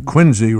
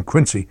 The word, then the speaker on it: Quincy: What it was that day, we were staying out, as they pronounced it in Boston, Quincy rather than Quincy.